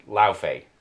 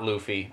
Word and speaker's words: Laufey: Number one, the name of the giant Laufey is pronounced Laufey. So it's not Luffy, unfortunately.